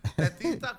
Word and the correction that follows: Fefita: ¿cómo se dice? Fefita la feta.